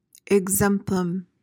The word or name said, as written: exemplum